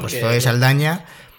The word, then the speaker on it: Saldaña: por cierto, me enteré, me enteré ayer de que Zoe Saldana no es Zoe Saldana, sino es Zoe Saldaña. Es con ñ el apellido. Lo que pasa es que, claro, los americanos son un poquito imbéciles y no, no saben pronunciar la ñ.